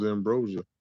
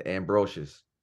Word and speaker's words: Ambrosius: I thought it was Ambrosia. – Yeah, Ambrosius.